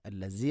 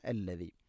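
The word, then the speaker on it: അല്ലദി (الذي): ഹുവല്ലാഹുല്ലദി ഹുവല്ലാഹുല്ലദി അല്ലദി ദാലിൻ്റെ ഉച്ചാരണം ശ്രദ്ധിക്കുക. അല്ലസി അല്ല അല്ലദി.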